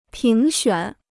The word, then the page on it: 评选: píng xuǎn